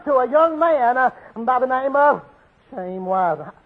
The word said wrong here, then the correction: Chaim: I ain't saying it right. How you say it? – Chaim. – Just like that.